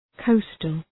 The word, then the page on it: coastal: 'kəʋstəl